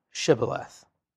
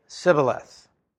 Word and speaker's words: Shibboleth: They would say, "Well, say Shibboleth." And apparently the men of Ephraim would say Sibboleth. And when you pronounced that, when you said that wrong, you died.